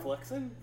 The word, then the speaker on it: Floxen: Floxen? – Floxen. F-L-O-X-E-N. – Which way is it pronouncing correctly? Floxen. – Floxen. – Floxen. – Floxen. – Mm-hmm. – Like a flock of oxen.